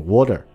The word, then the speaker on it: water: 那么比如说像这个 water 这个词，那么呃英国人呢，大部分的英国人呢，或者说标准的发音是 water，会把这个 t 这个音发的很重，但是伦敦人呢，会直接就把这个 t 就省略掉，就变成 water。